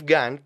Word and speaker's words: Gahan: Bueno, después habla en contra de Dave Gahan, que por cierto se pronuncia Gahan, no Gahan, el frontman de Depeche Mode, que en mi opinión es uno de los mejores frontmans de la historia.